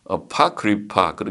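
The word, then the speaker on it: Apocrypha: 외경, 아포크리파, 그래서 아포크리파, 아포크리파라는 책이 있네. 아파크리파 그렇게 발음을 하네.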